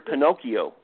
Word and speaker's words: Pinocchio: Well, if you take the word Pinocchio, which is actually correctly pronounced Pinocchio, what you get is piano key.